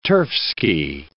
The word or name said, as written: turfski